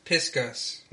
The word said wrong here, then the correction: Pisgah: No, Pisgah. P-I-S-G-A-H. – Pisgah. – What? Pis? – Pisgah. Pisgah. – What are you saying? – Pisgah? – There's no way you think that you're saying it right. – Pisgah. – No. – What are you saying? – There's no consonant. Look, you're putting a K sound.